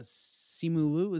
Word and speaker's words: Simu Liu: I mean, I think uh Simu Liu, is that how you pronounce it? – Yeah, I've never so.